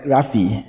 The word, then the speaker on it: graphe: It is spelled G-R-A-P-H-E. It sounds like graphe, but because of the apostrophe on top of the E, it is graphe. The graphe is singular, but the plural is grammar. G-R-A-M-M-A.